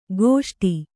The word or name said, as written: ಗೋಷ್ಠಿ